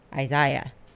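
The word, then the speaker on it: Isaias: I'm here for a couple months and we are waiting on tropical hurricane Isaiah. I think the S is silent at the end.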